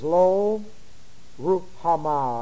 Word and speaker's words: Lo Ruhamah: All right, her name is Lo Ruhamah. I'll give you the spelling of her name for your notes. And her name is spelled L-O-R-U-H-A-M-A-H.